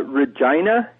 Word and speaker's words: Regina: I know Fred's not there, but I'd just like to say Regina is how you pronounce the town in Saskatchewan. It's a little more like that part of the anatomy that you guys always giggle about when that...